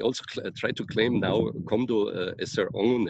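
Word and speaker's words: Komdo (검도): But even here in the, well, they pronounce it Komdo, Kendo Komdo in Korean, and it's usually written Kumdo.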